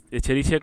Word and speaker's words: Escherichia: ¿Cómo se dice? – Escherichia coli. Es el como el... – Es la más básica, ¿no? – Como el que hacía grabados, ¿te acuerdas? Escher. Pero es el Escherichia.